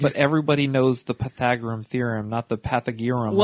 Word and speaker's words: Pythagorean: Is that how you say it? – Pythagorean. – Pythagorean. Okay.